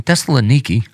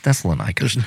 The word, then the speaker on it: Thessalonica: I was listening to a thing today, and they were like, in Thessaloniki. And I was like, it's Thessalonica.